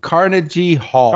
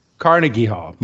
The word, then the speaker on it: Carnegie Hall: 2 shows at Carnegie Hall. – Carnegie Hall. – My bad. I knew I was going to say it wrong.